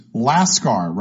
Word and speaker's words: lascar: Lactam, L-A-C-T-A-M, is a chemical compound. Lacuna, L-A-C-U-N-A, is an empty space. Lascar, rhymes with NASCAR, L-A-S-C-A-R, is an East Indian sailor. The other anagrams are crawls, C-R-A-A-L-S, which is to pen in an enclosure.